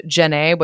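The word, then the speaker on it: Djenné: It's the Great Mosque of Jeannet, which is spelled D-J-E-N-N-E.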